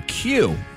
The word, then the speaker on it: queue: Spelled Q-U-E-U-E, pronounced one letter, Q.